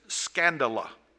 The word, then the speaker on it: skandala: Now, the Greek word for offense is skandala. That's the way that we would pronounce it in the English.